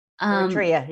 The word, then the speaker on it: Eritrea: They're coming from Afghanistan, Syria, from, I hope I pronounced it right, Eritrea. – Eritrea, yeah.